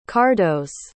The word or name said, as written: kardos